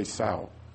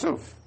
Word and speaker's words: sough: Of course, some say sow. That's disgusting. I doubt it too. S-O-U-G-H. That's the English word, which is pronounced suf.